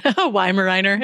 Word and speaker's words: Weimaraner: Weimaraner.